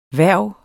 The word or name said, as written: hverv